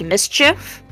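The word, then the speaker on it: MSCHF: So, a few months ago at this point, there is a brand called, I believe it's just pronounced Mischief, but it's M-S-C-H-F.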